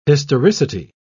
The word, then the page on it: historicity: British English